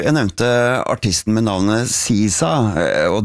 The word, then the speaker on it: SZA: S-Z-A. Yeah. S-Z-A, how do you go about pronouncing this? Well, it's pretty straightforward once you know. SZA. SZA. SZA. SZA. Pretty easy. SZA.